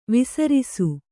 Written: ವಿಸ್ರಸೆ

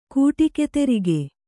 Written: ಕೂಟಿಕೆತೆರಿಗೆ

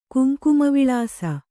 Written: ಕುಂಕುಮವಿಳಾಸ

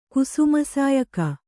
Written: ಕುಸುಮಸಾಯಕ

